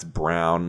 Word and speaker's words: Braun: I wish everyone just spelled their name how you say it, because it's like clearly spelled Braun, and that's what I thought it was for a long time. And now everyone's like, nah, it's Brown.